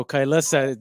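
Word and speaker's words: Kylesa: And how do you say their name? I've always said their name, Kailasa. I don't know if this is right. – Yeah, I think that's the way to pronounce it.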